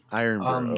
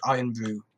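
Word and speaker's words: Irn-Bru: Okay, so obviously, Iron Brew. Okay. – In Scotland, there's a drink called Iron Brew. It's spelled IRNBRU.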